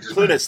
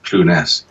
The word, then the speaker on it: Clunes: But yeah, I don't think it's a right or wrong or way of saying that. But I think he chooses to use Clunes.